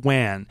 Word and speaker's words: WAN: Uh, he is the CEO of Simple WAN. And now, if you're not an IT person, you'll probably think it's Simple WAN because it's spelled W A N, but WAN is a wide area network.